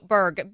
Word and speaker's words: Bourg: Oh, Berg. B-O-U-R-G.